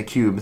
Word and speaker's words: Qube: That does not say cube. That says Q-U-B-E. So Q-U-B-E. – How do you pronounce that? – Q-U-B-E. – Cube.